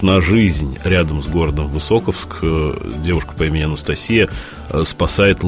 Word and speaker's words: Высоковск: А мы в Высоковске. Я, кстати, узнал, что ударение Высоковск правильное, не Высоковск. Я почему-то раньше говорил Высоковск, то правильно Высоковск.